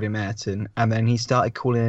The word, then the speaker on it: Ayrton: So if your kid is called Ayrton, your kid has got to say, no, it's not Ayrton, it's Ayrton. – Is that how it's actually pronounced, is it? And it's pronounced Ayrton.